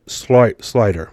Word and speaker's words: Sluyter: Now, he says, here's good evidence that a strongly African genetic marker shows up really early, says Louisiana State University geographer Andrew Schloyer. I know I said that wrong. Andrew S-L-U-Y-T-E-R, Slider, excuse me, who was not involved in the research.